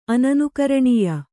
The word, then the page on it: ಅನನುಕರಣೀಯ: Kannada; ananukaraṇīya